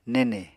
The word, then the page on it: nene: Apatani